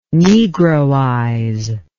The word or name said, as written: negroize